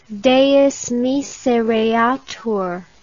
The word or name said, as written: Deus misereatur